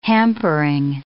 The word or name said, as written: hampering